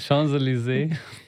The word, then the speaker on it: Champs-Élysées: Und da war ich auf der Champs-Élysées. Ja, ich kann ja Sachen nicht so gut aussprechen, die wir Champs, wissen. Champs-Élysées. – Genau, ja.